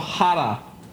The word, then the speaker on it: Chara: Just like Zdeno Chara, Zdeno Hara.